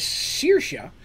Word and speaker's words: Saoirse: Just like I've mentioned before, uh, the goddess Saoirse is S A O I R S E, which again is an Irish spelling of Saoirse.